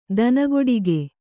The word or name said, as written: ದನಗೊಡಿಗೆ